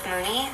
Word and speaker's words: Mooney: Yes, my name is Dr. Elizabeth Mooney, and it's spelled M-O-O-N-E-Y.